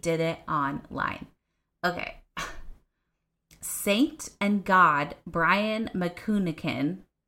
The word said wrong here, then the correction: Manookian: I'm going to spell that one because I did not say it right. M-A-N-O-O-K-I-A-N. Manukian. Like, that was not that hard. I just had to spell it first.